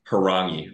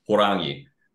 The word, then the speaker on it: Horangi: I corrected him at one and was like it's harangi or if you want to roll it horangi and he was like